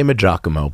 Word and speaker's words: Maggiacomo: We say Maggiacomo, but that's not wrong.